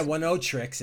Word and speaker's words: Oneohtrix: Yeah, One O Tricks is O-N-E-O-H-T-R-I-X, like One O Tricks, one word, point, never.